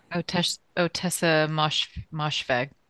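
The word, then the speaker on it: Ottessa Moshfegh: Otessa Moshfegh.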